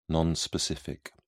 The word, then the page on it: non specific: British English